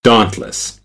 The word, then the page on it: dauntless: American English; ɑː